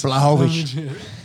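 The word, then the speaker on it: Błachowicz: Blachowicz.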